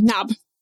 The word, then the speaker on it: Nob: Is that with the silent K? – No, N-O-B, Knob. – Oh, amazing. Oh, wow. Even better. – It's in the book. – Yeah, you pay the letter. We don't need a silent K.